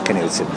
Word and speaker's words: Knudsen: She's like, she's like, um, it's Matt, Matt Newton. And uh, uh, I was like, believe it or not, it's it's it's pronounced Knudsen.